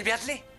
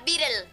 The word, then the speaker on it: Beatle: ¿Y si viene el Beatle? – Se pronuncia Beatle.